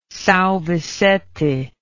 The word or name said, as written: Sao Vicente